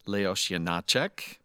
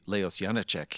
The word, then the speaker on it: Leoš Janáček: following in the steps of composer Leoš Janáček, as the Czech pronounce it, usually Leoš Janáček here in the U.S.